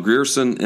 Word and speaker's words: Grierson: Will has a podcast called Grierson and Leach for the New Republic, where Tim Grierson, am I saying that right? – You are.